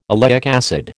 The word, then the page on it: oleic acid: American English